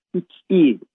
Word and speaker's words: Xi: X-I harfleri de Xi diye okunuyor. O da biliyorsunuz Çin Devlet Başkanı'nın adı.